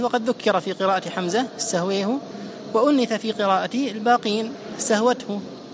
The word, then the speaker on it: استهوته: هو يقرأها بإمالة الألف، استهويه استهويه، وحتى تضبط المسألة كيف تنطق بحرف الأي في اللغة الإنجليزية، مثله تماما، هذه الإمالة الكبرى.